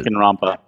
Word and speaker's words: Danganronpa: Danganronpa.